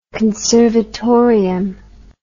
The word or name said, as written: conservatorium